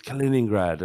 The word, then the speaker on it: Kaliningrad: I mispronounced Kaliningrad or something like that. – Yeah. – And you said, it's Kaliningrad.